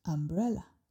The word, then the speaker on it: umbrella: So in English, how do we say this word umbrella in a natural way? Do we say umbrella or umbrella or umbrella? Yes, the natural and the correct way is umbrella.